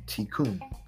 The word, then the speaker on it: tikkun: by Kabbalist tikkun, pronounced tikkun. Put out good and you will receive good.